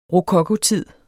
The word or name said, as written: rokokotid